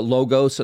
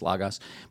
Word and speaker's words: logos: Most people pronounce it logos, and I'll give you an anglicized version, logos. That's actually not how it's pronounced in the original language. It's logos. But nonetheless,